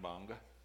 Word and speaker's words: Bonga: That word that starts with a G, a little red dot, that's pronounced Bonga.